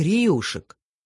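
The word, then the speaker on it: реюшек: Иногда, пролетая над Каспийским морем, он едва не касался брюхом самолета мачт рыболовецких риюшек, маленьких рыбацких парусников. При произнесении этого слова ударение следует делать на «ю».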